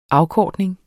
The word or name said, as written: afkortning